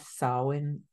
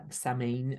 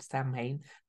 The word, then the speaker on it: Samhain: And of course, we do have Samhain, or Samhain, as it's spelled, Samhain, but it's Samhain, coming up really officially when the sun hits the 15 degree mark.